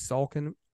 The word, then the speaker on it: Salkin: And last name is Salkin, S A L K I N.